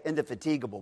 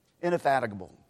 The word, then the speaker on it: indefatigable: And so pray, pray that the longing to love together would be indefatigable. Did I say that right? Did I pronounce it right? Indefat- indefatigable. Okay? I always want to say indefatigable, but that's not how you say it. Indefatigable.